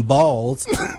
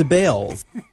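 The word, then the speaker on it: Baals: After all, the family did change the pronunciation of their last name from Balls to Bales.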